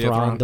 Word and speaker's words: Thrawn: I know Thrawn. That's how you say his name, right? – Thrawn, the blue guy.